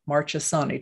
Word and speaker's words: Marchesani: So let me tell you a little bit about Courtney Marchesani. Did I say that right, Courtney? – It's a hard CH, so it's okay, Marchesani because it's Italian. Yeah, it's like Chianti.